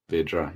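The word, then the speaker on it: Vydra: Is it Vedra at Burnley? – Vedra. – Vidra, yes, at Burnley.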